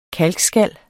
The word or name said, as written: kalkskal